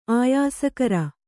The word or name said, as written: ಆಯಸ್ಕರ